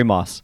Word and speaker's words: Maass: Yes, Corey Maas.